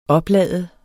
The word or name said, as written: oplade